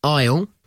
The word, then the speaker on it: isle: Five words with hidden S words, silent S words. Isle. – Yes. – Island. – Yeah. – Debris. – Yeah. – Apropos. – Oh, nice. – And one I think you really like, bourgeois.